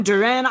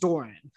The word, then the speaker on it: Duren: They traded number thirteen, where that was the Jalen Duren pick, and I actually would have loved Jalen Duren Doran, Duran. I'm not, it's spelled like Dur- Duran, but it's pronounced Doran.